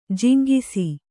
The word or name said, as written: ಜುಂಗಿಸು